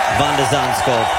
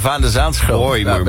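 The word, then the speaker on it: van de Zandschulp: Van der Zaanschulp. What an incredible comeback. – Ja, ik vind dat ze zijn naam ook goed uitspreken. Van der Zaanschulp.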